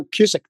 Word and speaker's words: Cusick: No, Cusick, the way you said it.